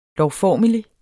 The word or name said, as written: lovformelig